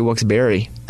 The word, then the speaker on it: Wilkes-Barre: The second half is spelled B-A-R-E, but people say Wilkes-Barre, but it's actually Wilkes-Barre.